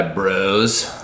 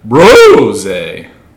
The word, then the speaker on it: Brose: Bróse, drink by bros. – Yeah. Well, no, I'm not a bro. – I know, but like it's spelled B-R-O-S-E. Yeah. So it's like bros. – No, it's bROse.